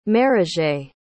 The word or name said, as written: maraget